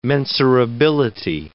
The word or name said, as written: mensurability